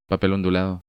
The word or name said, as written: Papel ondulado